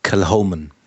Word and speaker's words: Kilchoman: Also das H spricht man doch. – Ja, und das I würde ich wie ein E aussprechen. Kel? Äh, das H äh, wird dann, also das C wird verschluckt. – Mhm. – Äh, und dann Calhoman. Calhoman.